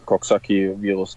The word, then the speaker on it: Coxsackie: Der Virus heißt Coxsackie-Virus. Ist das so richtig ausgesprochen? – Ja, Coxsackie-Virus, genau. Ja, der hat halt im Endeffekt eine Entzündung im ISG im Rücken verursacht, wodurch ich diese Probleme hatte.